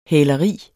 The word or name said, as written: hæleri